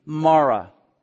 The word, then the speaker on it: Mara: Call me Mara. That is a Hebrew word, and that's the Hebrew pronunciation.